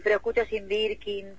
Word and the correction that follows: Jane Birkin: Jane Birkin, sí, buenísimo.